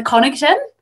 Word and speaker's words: Connaughton: Connaughton. It's Irish, so I'm happy it's Irish. So you got to put an Irish accent, Heidi.